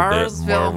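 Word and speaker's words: Maryville: We have to address this, because Heritage High School is in a town called Marysville, but it's not pronounced like that. – Nah, you kind of got to put a marble in your mouth and roll your tongue a little Marysville, bit.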